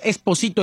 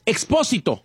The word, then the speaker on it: Expósito: No es Expósito, es Expósito. – Expósito, ok.